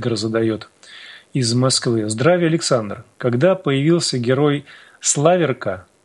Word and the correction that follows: Славирка: думал диалоги... – Славирка. – Ну, Славирка, хорошо. Здесь просто не ставится нигде ударение.